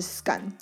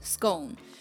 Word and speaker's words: scone: 哇，scun 听起来很像是 s s k u n 之类的，scun，就它的呃发音方式跟枪 g u n 的母音发音方式是押韵的。 – gun scun，所以有三种，有我们习惯的 bone scone，然后有这上面说 rhymes with gone 的 scone，然后还有那个 gun scun。